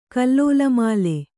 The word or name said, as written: ಕಲ್ಲೋಲಮಾಲೆ